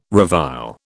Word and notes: revile: British English

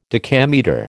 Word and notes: decametre: American English

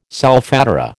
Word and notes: solfatara: American English

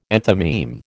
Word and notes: enthymeme: American English

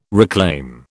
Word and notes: reclaim: British English

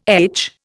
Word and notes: etch: Indian

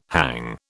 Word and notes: hang: British English

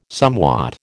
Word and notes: somewhat: American English

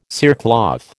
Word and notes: cerecloth: American English

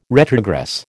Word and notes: retrogress: American English

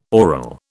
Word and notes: oral: British English

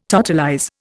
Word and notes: totalize: Indian

